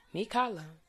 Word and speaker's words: Mikala: They called me Michaela because my grandmother named me Mikala. But that's the thing. It looks like Michaela in whatever language, I guess English language, is going to be pronounced that way. But it is a Hawaiian name. So Mikala Leinani is how it is said.